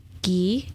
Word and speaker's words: ghee: It's called ghee. It's G-H-E-E. Is that how you say it? Is that how you pronounce it? – Sounds right to me.